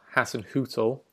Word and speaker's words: Hasenhüttl: So it's Ralph Hasenhüttl.